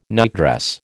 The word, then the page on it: nightdress: American English